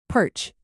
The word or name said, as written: perch